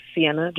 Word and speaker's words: Ceyenna: Yeah, so my handles on Instagram and Twitter are both Sienna, just my name, so C-E-Y-E-N-N-A, which is spelled a little differently, but I hope that you can all remember that.